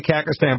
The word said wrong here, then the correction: Kazakhstan: but it's not Kakistan. It's like, I don't know. It's, you know. – Kazakhstan? – Yeah, thank you. Kazakhstan.